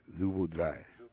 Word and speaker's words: je voudrais: Je voudrais. – All right, dry. D-R-A-I-S. Remember, people, the S is silent.